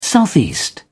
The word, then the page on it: southeast: British English